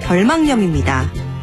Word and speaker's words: 결막염: 결막에 생기는 염증을 이르는 말, 발음을 주의해야 하는데요. 결막염이 아니라 결막염입니다.